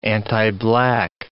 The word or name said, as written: antiblack